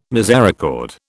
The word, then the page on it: misericord: British English